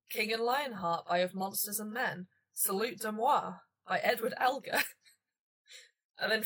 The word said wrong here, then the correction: Salut d'amour: I don't speak French. – Spell it. – S-A-L-U-T. – Yep. – D apostrophe A-M-O-U-R. – Salut d'amour. – There we go.